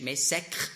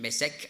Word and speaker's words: Mesech: Aber eben Mesech und Tubal, es ist möglich, dass Mesech, hebräisch Mesek ursprünglich, im Althebräischen Mesek ausgesprochen, mit Moskau zusammenhängt.